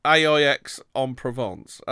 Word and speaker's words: Aix-en-Provence: Why don't you just trust the Aix en Provence? How do you pronounce that a bit? – Aix-en-Provence.